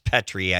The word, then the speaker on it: Petri: Tom, during the time, that they used Wisconsin as a petri dish to destroy democracy, we actually had a congressman, Petri, pronounced Petri, I guess, who went from being a millionaire to a multi-millionaire during his time in Congress.